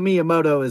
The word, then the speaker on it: Miyamoto: So just to clarify, it is Miyamoto is how you say his name. I think you pronounce Shigura, but I know Miyamoto is the correct last name.